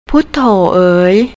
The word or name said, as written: putto